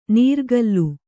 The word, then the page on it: ನೀರ್ಗಲ್ಲು: nīrgallu